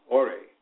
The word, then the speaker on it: Ori: In Yoruba culture, the soul is called Ori, pronounced Ore, or the spirit of the head.